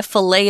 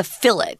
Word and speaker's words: fillet: For example, we always say a fillet of fish uh, in America, we actually pronounce that word fillet with a T at the end, mm -hmm. the mm-hmm. way the French would fillet. And they, we don't say that T.